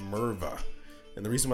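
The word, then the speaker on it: Murvagh: M-U-V-R-A-G-H. – But it's pronounced Merva. – M-U-R-V.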